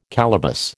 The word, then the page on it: calaboose: American English